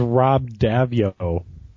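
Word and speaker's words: Rob Daviau: His name is Rob Davio.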